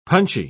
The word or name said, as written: punchy